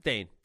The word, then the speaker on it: Thane: Thane? – Yes.